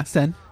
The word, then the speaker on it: Seine: Am I saying that right? – Yeah, Seine. – Seine River. – S-E-I-N-E.